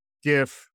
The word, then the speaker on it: GIF: You are not allowed to use the GIF. It's a GIF. I thought we talked about this. – I'm a hard G.